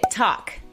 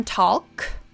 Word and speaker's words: talk: Mi esposo me regañaba porque no pronunciaba la L. Tuve que buscar la pronunciación y confirmé que la mía era la correcta. Muy. ¿Ustedes decían talk? Talk. Platicar o hablar. Talk. – No, en realidad la L no suena, que volvemos a lo que les dije al principio.